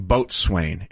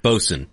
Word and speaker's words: boatswain: But we were playing audio from Norm saying boatswain. And that was actually a mispronounced word. It's actually bosun.